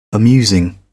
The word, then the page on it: amusing: American English